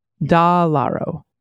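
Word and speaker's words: DeLauro: The correct way to say Rosa de Laro's name is Dalaro.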